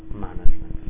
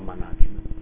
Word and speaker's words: management: Faccio presente l'accento di management, vi prego, management, non management.